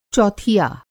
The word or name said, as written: चौथिया